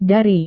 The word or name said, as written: dari